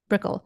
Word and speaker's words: Brickell: Brickell.